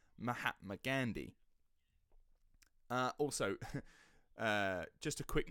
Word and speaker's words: Mahatma: I would normally, like, when I say Mahatma Gandhi, and I think this is a very South English thing. We really emphasise the A. – Mahatma.